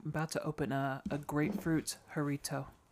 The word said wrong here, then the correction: Jarritos: I'm not saying it right, but jarrito. – Jarritos. – Jarritos.